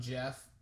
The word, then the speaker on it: Gef: You want to enlighten us on Gef? – I will. So Gef is actually spelled G-E-F. – Yes. Soft G. – Soft G. That's right.